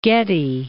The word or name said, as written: Getty